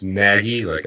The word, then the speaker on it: Nagy: Matt Nagy, it's pronounced Nagy, to nag someone, is the new head coach.